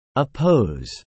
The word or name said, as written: oppose